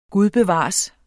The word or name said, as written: gud